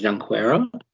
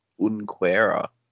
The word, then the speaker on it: Junqueira: Yeah, no, I think the proper Brazilian pronunciation is Junqueira. But, or is it a silent J, you know, like Unquera? – Great question.